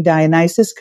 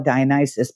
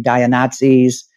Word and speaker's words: Dionysus: I thought it'd be really cool to call it Dionysus, but I didn't realize nobody could pronounce the name. I mean, we were called everything, Dionazis, dialysis, kind of funny. So we had to educate people from the get-go how to even pronounce our name.